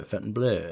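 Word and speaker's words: Fontainebleau: We were trying to figure out how to pronounce Fountain Blue. Tableau is the same spelling with a B-L-E-U. – Tableau, yes. – Tableau, Fountain Blue.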